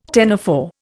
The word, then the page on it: ctenophore: Indian